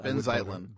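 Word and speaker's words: Benh Zeitlin: I would vote him because Ben Zeitlin.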